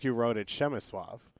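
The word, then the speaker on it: Przemysław: Shimasuav. Yes, that's the pronunciation.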